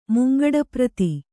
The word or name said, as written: ಮುಂಗಡಪ್ರತಿ